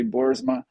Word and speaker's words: Boersma: Most people say Hans, and last name is usually Boersma.